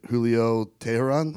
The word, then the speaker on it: Julio Teheran: But on the other side, Julio Teheran, mm-hmm.